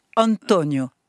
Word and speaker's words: Antonio: C'est une question d'accent tonique. Antonio.